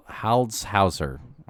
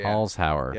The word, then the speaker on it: Holzhauer: Ken Jennings, Brad Rutter, and James Hal- Holzhauer. – I James, I said that right. That's what it is. Yeah, yeah, yeah. Holzhauer.